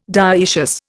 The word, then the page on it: dioecious: Indian